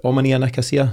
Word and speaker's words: Omonia Nicosia: I mean, there's a case there, and you're right in the face there, this week with Neil Lennon's, is it Omonia Nicosia? Is that how you pronounce that? – Yep.